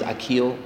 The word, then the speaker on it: Aquil: Sure, my name is Aquil Charlton.